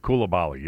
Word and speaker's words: Coulibaly: Coulibaly? Is that the way you pronounce his name? – Coulibaly, yeah. Bilal Coulibaly.